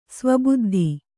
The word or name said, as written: ಸ್ವಬುದ್ಧಿ